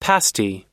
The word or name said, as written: pasty